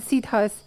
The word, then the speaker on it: siddha: And he asked about, in one lecture, you told us about the siddhas, the high souls, and their connection.